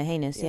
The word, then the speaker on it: heinous: So that means that even as heinous, is that how you pronounce it? – Yeah, heinous, yeah. – Okay, I don't know why that sounded. It sounded like anus. – It sounds weird, yeah. Well, it does sound like anus.